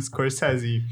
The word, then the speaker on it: Scorsese: Wymawia się to Martin Scorsese.